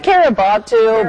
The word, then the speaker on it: Kiribati: Did I say that right? – Kiribati, but I've heard that.